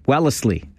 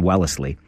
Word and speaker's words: Wellesley: Wellesley, as they say in Wellesley. You have to add the extra syllable in Wellesley.